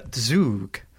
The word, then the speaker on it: Zug: That is not how you pronounce that, apparently. It is Zug.